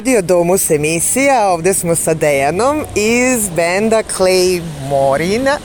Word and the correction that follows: Claymorian: Claymorian. – Ipak nisam uspela da izgovorim, hvala.